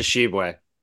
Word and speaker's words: Tshiebwe: Oscar Tshiebwe.